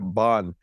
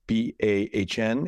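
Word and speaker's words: bánh: And I think that over the years, it's just made it much easier to pronounce when it's BAHN, and it makes sense and phonetically for English speakers to call it bon when you flip that N in the H.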